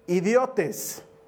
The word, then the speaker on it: idiotes: viene de un vocablo en griego que se pronuncia idiotes.